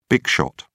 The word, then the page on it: big shot: British English